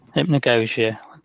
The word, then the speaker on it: hypnagogia: I mean, we've got to talk, obviously, hypnagogia. Did I say it right? – Yes. Yes, it is.